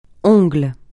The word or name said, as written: ongle